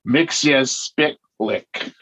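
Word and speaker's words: Mxyzptlk: In the episode, Gilbert Gottfried as the character himself pronounces it Mixit-Splitwick.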